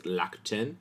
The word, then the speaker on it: Rakuten: I had the privilege of taking Japanese for 6 years in high school and college, so I can tell you it's pronounced Rakuten, not Rakuten, but that's neither here nor there.